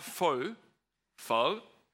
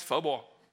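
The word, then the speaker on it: phở: That's how you say it, pho bao. Not pho, pho. Okay. And I must confess, this is not a picture of Tran's pho bao.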